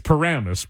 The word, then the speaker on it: Paramus: But right, it's Paramus. But I could see them putting the accent on the wrong syllable.